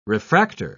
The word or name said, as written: refractor